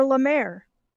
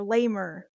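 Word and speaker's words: Cure La Mer: It is Cure Lemaire. – Cool. – But it's spelled out, so it really looks like her name is Cure Lamer.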